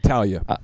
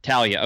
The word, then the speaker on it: Taulia: I believe his name is Taulia. I don't know how to say his name. But Taulia. – Taulia, okay.